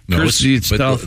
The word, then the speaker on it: Krzysztof: No, there's another syllable in Krzysztof. It's Christy stuff.